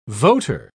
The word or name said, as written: voter